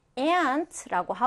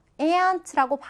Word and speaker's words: aunt: 지금 두 가지 발음이 있는데, 그냥 우리가 미국식으로 발음하면 aunt라고 하고요. 또, 이거는 어떻게 발음할까? 네, 영국식으로는 aunt라고도 합니다. 그래서 우리가 많이 섞어서 써요. aunt라고 발음하는 사람도 있고, aunt라고 하는 사람도 있습니다. 두 가지 발음 다 가능하다라는 거 알아두시고요.